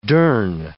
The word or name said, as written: durn